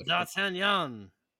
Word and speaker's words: D'Artagnan: I wasn't gonna say all that, but say it with a French accent. Because you're not saying it right. – Oh, D'Artagnan.